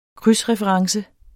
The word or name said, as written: krydsreference